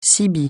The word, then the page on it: CB: francais